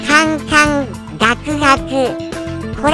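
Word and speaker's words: カ ン カ ン ガ ク ガ ク (侃々諤々): カンカンガクガクこれが正しいらしいです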